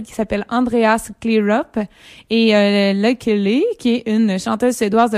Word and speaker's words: Lykke Li: C'était la pièce « Until We Bleed » de Clear Up avec la voix de Lucky Lee. En fait, on aurait tendance à prononcer « Lucky Lee », mais comme c'est une chanteuse suédoise qu'on entend sur cette pièce-là, il faut prononcer « Lucky Lee ».